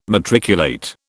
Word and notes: matriculate: British English

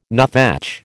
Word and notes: nuthatch: American English

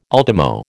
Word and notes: ultimo: American English

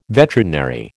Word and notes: veterinary: American English

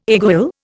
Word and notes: aiguille: Indian

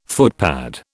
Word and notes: footpad: British English

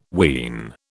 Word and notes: ween: British English

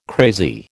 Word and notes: crazy: American English

